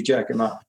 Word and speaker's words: Giacomo: Yeah, you can pronounce it guacamole, but he's from Italy and it's meant to be Giacomo.